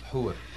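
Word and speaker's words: houri: Huris, H-O-U-R-I-S. How do I pronounce that? – Hura.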